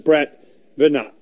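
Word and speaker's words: Brett Veinotte: But today we're going to spend a lot of time with Brett Vinat.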